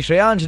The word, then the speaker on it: श्रेयांश: RJ श्रेयांश dash red FM के नाम से मिलूंगा, नाम की स्पेलिंग है SHREYANSH।